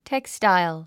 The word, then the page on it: textile: tékstail; テクスタァイゥ